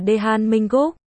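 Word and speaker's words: Dae-han Minguk (대한민국): TL, Hàn Quốc trong tiếng Hàn đọc là Dae-han Minguk.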